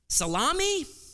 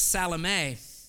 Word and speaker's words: Salome: You look and say, is that Salome? Salami? Well, I think Salome sounds the most feminine. So we'll go with Salome.